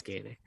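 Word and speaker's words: Guyenet: Dr. Stephen Guyonate or something, like, I can pronounce him. – I think it's Gainey.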